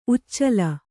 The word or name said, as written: ಉಚ್ಚಲ